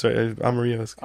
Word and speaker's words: Amarillo: No, I pronounced it the Spanish. – Yeah, no, I'm sure it's Amarillo.